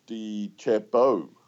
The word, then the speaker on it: DeChambeau: And I've backed my boy Bryce DeChambeau.